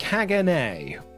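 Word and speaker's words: Caganer: It is pronounced Kagane.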